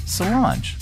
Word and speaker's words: Solange: Solange? Solange? I know people are going to be yelling at their screens. How to pronounce? Let's see. It's our Pronouncify lady. – Solange.